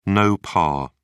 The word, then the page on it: no par: British English